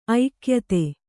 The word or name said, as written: ಐಕ್ಯತೆ